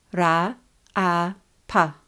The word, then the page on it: Raaapa: Raa–a–pa